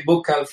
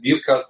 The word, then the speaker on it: buccal: What's the proper pronunciation of that? – Some people say buccal fat removal. Some people call it buccal fat removal, either one.